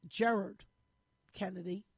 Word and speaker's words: Gerard Kennedy: And we're going to go off and talk with Jared Kennedy, If he lived here, we'd call him Gerard. But there, the accent is on the first syllable. So it's Gerard Kennedy.